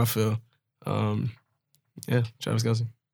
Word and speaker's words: Kelce: Uh, Travis Kelce. Kelce.